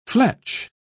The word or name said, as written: fletch